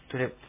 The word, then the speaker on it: トゥレップ: そのトゥっていうのがですね、まあ、外来語などで英語のトゥモローとかトゥデイとか言いますけどね、それと同じで、トゥという発音です。トでもないし、ツでもないです。トゥという。カタカナで書くときは、トに丸をつけて書くことが多いんですね。もしくはトにちっちゃいをつけて書きます。日本語にはない発音なんで、ちょっと、難しいかもしれません。トゥレップですね。